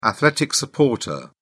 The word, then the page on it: athletic supporter: British English